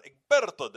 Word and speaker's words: Egberto: Egberto, not Egberto, but Egberto, the Spanish pronunciation.